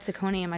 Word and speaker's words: syconium: And for some reason, I put scrotum in parentheses behind syconium because I think when I was like reading articles about this, every time I read syconium, I just read scrotum.